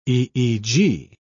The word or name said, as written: eeg